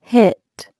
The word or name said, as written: hit